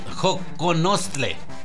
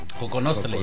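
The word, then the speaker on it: Xoconostle: Dice por acá Giselle Moreno, se escribe con X, pero se pronuncia con Joconostle. Joconostle, ya ves cómo se pronuncia.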